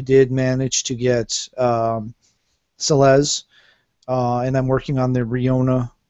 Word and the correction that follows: Rinoa: I've always thought it was Riona. I've never actually looked at the name carefully enough to realize I've been mispronouncing it this entire time. – Rinoa.